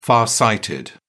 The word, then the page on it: far sighted: British English